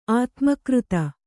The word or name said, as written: ಆತ್ಮೀಕೃತ